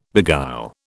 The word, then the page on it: beguile: British English